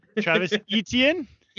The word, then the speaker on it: Etienne: Travis Etienne.